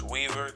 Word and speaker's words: Weaver: My name is Weaver.